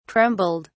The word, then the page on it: trembled: British English